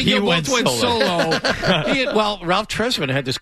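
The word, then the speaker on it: Tresvant: Trezvant, or how do you pronounce it? – Ralph Trezvant.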